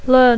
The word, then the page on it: learn: English